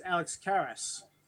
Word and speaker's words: Alex Karras: The actor who played Mongo is Alex Karras. – Yep. – I think I'm saying his name right. – Yes.